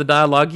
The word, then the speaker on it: dialogue: You have issues with the dialogue. – You're putting the wrong emphasis on the wrong syllable. – Sorry. You have problems with the dialogue.